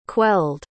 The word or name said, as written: quelled